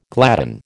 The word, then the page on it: gladden: American English